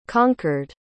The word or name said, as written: conquered